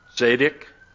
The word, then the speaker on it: tzedek: So I, you know, in Hebrew, righteous is tzedek. Did I say it right? Okay. They said it.